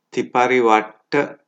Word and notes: tiparivatta: Pali

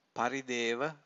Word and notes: parideva: Pali

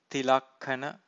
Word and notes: tilakkhana: Pali

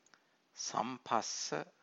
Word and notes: samphassa: Pali

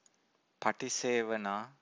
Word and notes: patisevana: Pali

